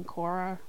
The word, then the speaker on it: Korra: The nickname, I would say my family mainly just call me Korra with a K. You know, like the legend of Korra from Avatar or something like that.